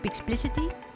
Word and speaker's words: Pixplicity: You are the founder and the CTO of Pixplicity. – Yeah. – Do I pronounce it well? – Yes, you do.